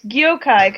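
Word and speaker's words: Gjokaj: Then it's Gyokai, because the J's are pronounced as Y's in the Balkan.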